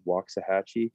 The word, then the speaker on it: Waxahachie: Waxahachie.